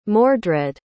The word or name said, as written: mordred